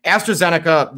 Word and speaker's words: AstraZeneca: But on top of that, and I'm going to AstraZeneca. Okay, that is how you pronounce it.